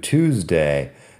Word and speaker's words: Tuesday: Tuesday, la primera sílaba es two, como el número, si quieres. Two Tuesday. Fíjate que la e no suena, así que no es como Tuesday ni nada de esto.